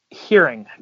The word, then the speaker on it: Heuring: It's... Heuring, kind of like hearing, but yeah, you're not the first to get to inadvertently mispronounce it.